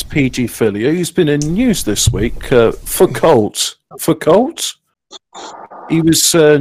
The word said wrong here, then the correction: Foucault: Uh, Foucault, isn't it?